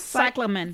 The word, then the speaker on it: cyclamen: Cyclamen.